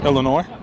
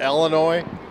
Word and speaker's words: Illinois: Illinois. – Again? – Illinois. – Now, it sounds like it's the way you pronounce it that is spelled E-L-L, like Duke Ellington, Illinois.